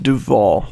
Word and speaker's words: Duvel: But it's a Belgian golden ale, pronounced Duvel, D-U-V-E-L. However that makes sense, I don't know,